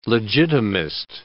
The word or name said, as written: legitimist